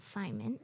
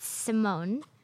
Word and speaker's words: Simone: And second of all, her name isn't Simon. It's Simone. – She changed her name to Simone. – It's always been Simone. – But it's spelled Simon.